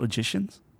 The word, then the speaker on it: logicians: So logicians, is that how you say it? Logicians? – Sounds like magicians.